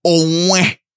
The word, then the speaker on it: Owen: Owen Odiase. If you didn't know, my middle name is Owen, but it's really Owen with that accent. Say it right.